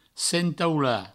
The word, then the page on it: sentaular: sentaula